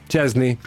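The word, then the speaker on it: Szczęsny: Come pronunci il nome del nuovo portiere della Roma, Pierluigi? – Chesney. – Sì, la pronuncia è corretta,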